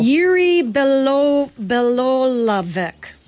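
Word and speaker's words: Jiří Bělohlávek: Jiří Belohlavek.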